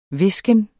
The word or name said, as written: hvisken